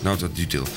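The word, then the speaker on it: Nauta Dutilh: In de studio hebben wij mevrouw Marijnissen, een advocaat van bureau Nauta, zeg ik het goed? Dutil. Uit Amsterdam. Nauta Dutil.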